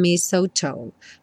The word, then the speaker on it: mistletoe: And mistletoe. Mistletoe. Tá? Não se confunda com esse T-L-E aí.